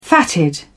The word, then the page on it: fatted: British English